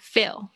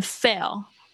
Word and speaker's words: fill: 啊，特别这边要注意一下 fill，嗯，很多同学念成 fill，或者是有 full。昨天我听到这两个发音都是不对的啊。如果你可以看到我的嘴巴的话，就是 fill。